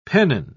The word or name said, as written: pennon